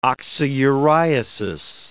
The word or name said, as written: oxyuriasis